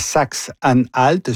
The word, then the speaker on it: Saxe-Anhalt: Donc, élection importante demain dans la Saxe-Anhalt.